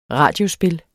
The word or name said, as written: radiospil